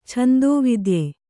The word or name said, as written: ಛಂದೋವಿದ್ಯೆ